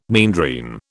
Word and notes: meandrine: British English

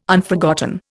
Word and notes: Unforgotten: Indian

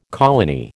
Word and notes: colony: American English

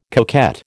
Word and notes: coquette: American English

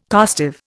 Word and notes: costive: Indian

